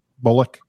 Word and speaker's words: Bullock: So Mark Bullock. Am I saying that right, by the way? That's the Bullock? – Yeah. Yeah, like, okay. Like Sandra Bullock.